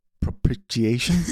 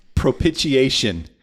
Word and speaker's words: propitiation: Was it propitiation? – That's it. Propitiation.